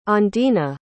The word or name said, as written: ondina